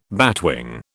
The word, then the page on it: batwing: British English